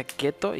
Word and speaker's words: Ceto: Se escribe Ceto, e, e pero se pronuncia Keto y no, no hace alusión a la dieta Keto porque pues yo soy enemigo de las dietas.